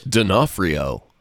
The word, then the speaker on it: D'Onofrio: Vincent D'Onofrio.